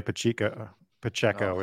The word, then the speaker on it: Pacheco: Speaking of which, Isaiah Pacheco is the way he pronounces it. New England, we pronounce it Pacheco.